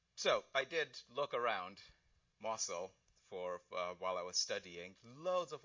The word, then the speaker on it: Mosul: The Iraqi city of Mosul, spelled M-O-S-U-L, so I would have pronounced that Mosul, but no, it's Mosul, stands where Nineveh used to be.